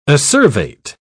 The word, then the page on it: acervate: British English